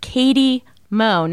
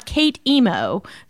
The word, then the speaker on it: K8EM0: If you want to stalk me on Twitter, it's Katie Mo, but it's spelled K8EM0 and pronounced Katie Mo, not Kate Emo.